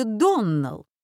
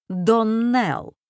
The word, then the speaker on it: Доннелл: «Моя дочь, Клариса Эльмира, сказала мне, что вы произносите нашу фамилию Доннелл. Так вот, мисс Ширли, правильное произношение нашей фамилии — Доннелл. Ударение на последнем слоге.